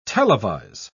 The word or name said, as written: televise